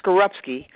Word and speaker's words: Skorupski: My last name is pronounced Skorupski.